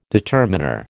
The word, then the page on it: determiner: American English